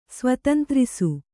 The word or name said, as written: ಸ್ವತಂತ್ರಿಸು